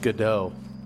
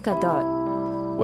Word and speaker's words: Gadot: Is that how you say her name? – I thought it was pronounced Godot. – I think it's Gadot.